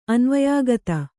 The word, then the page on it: ಅನ್ವಯಾಗತ: anvayāgata